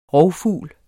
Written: rovfugl

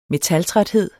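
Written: METALTRÆTHED

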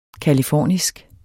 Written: californisk

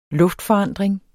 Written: luftforandring

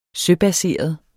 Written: søbaseret

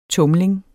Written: tumling

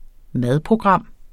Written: MADPROGRAM

